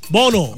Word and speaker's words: Bono: El arquero Bonus tapando. Bonus. – Bono. B-O-N-O, así se escribe.